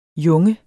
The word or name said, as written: junges